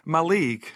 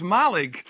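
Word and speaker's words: Mallaig: And I was talking last weekend about places that we sometimes say incorrectly, and I mentioned that ScotRail, well, has most of the country saying Malaig. However, it is pronounced Malaig, but to get to Malaig by road, you have to leave Fort William, head across the Blar, and then down the A830.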